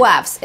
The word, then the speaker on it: kvass: Quaffs. It's quaffs. K-V-A-S-S.